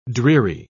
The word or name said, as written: dreary